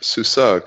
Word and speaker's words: SUSE: SUSE, pronounced SUSE, comes from the German acronym Software und Systemwicklung.